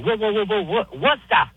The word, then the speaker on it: Worcester: Worcester. Yeah, two syllables. Worcester.